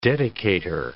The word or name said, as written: dedicator